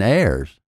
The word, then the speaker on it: eirs: If that isn't comfortable, they suggest you use EY, EM, or EIRS, pronounced I, M, and airs.